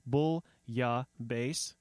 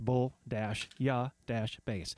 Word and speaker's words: Bouillabaisse: This is Pandora's Lunchbox, and those were the DeMarco sisters and Bouillabaisse, helpfully with the pronunciator on the label, Bouillabaisse. It's French. Pronounce Bouillabaisse.